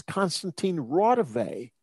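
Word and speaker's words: Konstantin Raudive: This is not, he pronounced it wrong. It's Konstantin Rodov.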